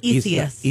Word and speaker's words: Isaias: By the way, I don't know if you've heard me in my headlines or not, but am I getting close to pronouncing the name correctly for Isaias? – It's Isaias. – Isaias. – Yeah, is that so? I'm doing it right? – Yeah, Isaias.